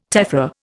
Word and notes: tephra: Indian

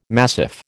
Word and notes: massif: American English

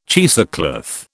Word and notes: cheesecloth: British English

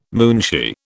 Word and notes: moonshee: British English

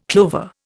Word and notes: clover: Indian

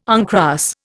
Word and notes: uncross: Indian

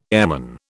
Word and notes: airman: British English

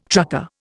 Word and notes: trucker: Indian

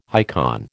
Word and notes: icon: American English

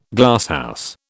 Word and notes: Glasshouse: British English

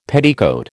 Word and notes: petticoat: American English

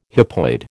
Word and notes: hypoid: American English

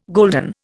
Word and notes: golden: Indian

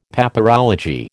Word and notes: Papyrology: American English

